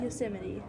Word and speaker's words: Yosemite: how do you say that? – Yosemite. – Oh, right, Yosemite National Park.